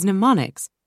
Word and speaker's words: mnemonics: One way you can significantly improve your memory is by using memory techniques often referred to as mnemonics. The first M is silent.